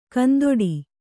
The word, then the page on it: ಕಂದೊಡಿ: kandoḍi